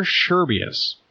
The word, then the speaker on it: Scherbius: S-C-H-E-R-B-I-U-S.